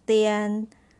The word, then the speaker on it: 点: And the word tien has the same consonant, which is d sounded as the, and the compound vowel i, a, and n, pronounced as ian or ian, ian, with the third tone put above letter a. So let's pronounce this word together. The ian, tian, tian, tian, tian. Third tone, you're going down and up.